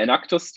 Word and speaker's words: Enactus: Wobei für Enactus gibt es so zwei Reden und Wir sagen immer Enactus, die deutsche Version.